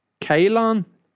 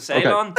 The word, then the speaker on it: Ceylon: Am I saying that right? Kaylon? Ceylon? Yeah. Ceylon? – Spell it. – C-E-Y. – Well, you're uptown yet, there's a Ceylon. – Okay. That's what it is.